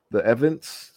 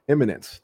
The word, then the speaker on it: Eminence: The speakers are the Evidence. I can pronounce it. – Eminence.